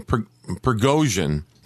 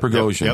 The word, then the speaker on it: Prigozhin: And it's the notorious Wagner group, whatever you want to call it, led by Yevgeny I can never say his name, Prigozhin. Okay? – Yeah, you got it. – Prigozhin.